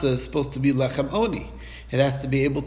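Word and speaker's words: oni (עני): It's written lechem ani. We pronounce it as oni, but it's still spelled with an ayin, and therefore he doesn't darshan that it has to be able to be eaten in mourning.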